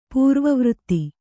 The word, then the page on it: ಪೂರ್ವವೃತ್ತಿ: pūrva vřtti